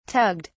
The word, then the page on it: tugged: British English